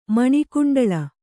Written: ಮಣಿಕುಂಡಳ

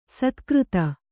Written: ಸತ್ಕೀರ್ತಿ

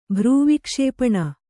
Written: ಭ್ರೂವಿಕ್ಷೇಪಣ

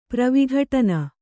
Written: ಪ್ರವಿಘಟನ

